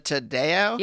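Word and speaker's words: Taddeo: It's Lisa Tadeo.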